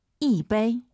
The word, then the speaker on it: 一杯: 一杯. – 오, 청취자분들 지금 이杯 일성으로 하시는 분 계시네요. 숫자 1 다음에 일성이 오면은 숫자 1이 사성으로 바뀝니다. 이杯 이렇게 발음하세요.